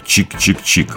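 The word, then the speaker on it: !!!: И диско-панк ансамбль Сакраменто с названием из трех восклицательных знаков, которые следует произносить как «чик-чик-чик».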